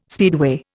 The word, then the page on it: speedway: Indian